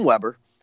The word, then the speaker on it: Weber: I mean, if you're talking about Weber, I prefer the German pronunciation. I try to always improve my German. Max Weber, but we can call him Weber because I think that's what they say in American schools.